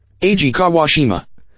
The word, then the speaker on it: Eiji Kawashima: Eiji Kawashima.